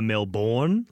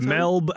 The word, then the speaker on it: Melbourne: And it's not I think a lot of sort of North American people do like a Melbourne, like they put emphasis on the second, on the born.